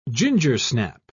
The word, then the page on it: gingersnap: British English